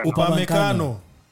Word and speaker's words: Upamecano: I mean, Van de Beek, Upama, I don't know how to say his name, Upama. – Yeah, Upamecano.